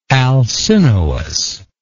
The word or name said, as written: alcinous